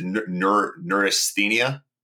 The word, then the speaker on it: neurasthenia: I'm going to mispronounce it. What is it? Neurasthenia? Yeah, yeah.